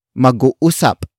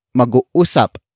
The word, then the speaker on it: mag-uusap: The first word is mag-uusap. Mag-uusap. Mag-uusap. Mag-uusap is spelled as M-A-G hyphen U-U-S-A-P.